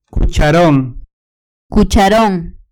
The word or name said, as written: cucharon